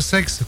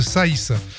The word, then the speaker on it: Saix: Le Tarn à l'honneur ce matin avec la commune de Saïs. – Oui, Saïs, et non pas sexe, comme certains visiteurs le prononcent. – Évidemment. – Ça s'écrit S-A-I-X, mais se prononce Saïs.